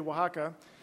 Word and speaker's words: Oaxaca: Difficult for a lot of people to say, but I was telling people, if you can say Wapaca, you can say Oaxaca.